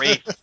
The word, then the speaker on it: Ralph: Like, some people call him, like, Rafe. Some people call him Rafe, but I figure if it was good enough for his mother to call him Ralph, I'll call him Ralph.